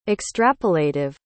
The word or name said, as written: extrapolative